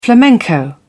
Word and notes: flamenco: British English

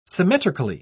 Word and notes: symmetrically: British English

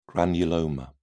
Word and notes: granuloma: British English

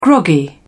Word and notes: groggy: British English

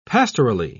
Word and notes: pastorally: British English

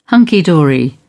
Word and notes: hunky dory: British English

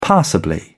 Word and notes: passably: British English

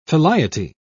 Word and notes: filiety: British English

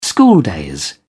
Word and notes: schooldays: British English

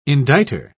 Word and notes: indicter: British English

